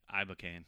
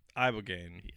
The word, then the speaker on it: Ibogaine: Ibogaine. – Ibogaine. – Ibogaine or cane? Gain.